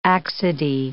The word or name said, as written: accidie